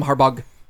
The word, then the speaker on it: Harbaugh: Jim Harbaugh, there's a GH at the end. – Yeah. It's actually Jim Harbaugh. – Right. – That fast, too, is how you say it, too.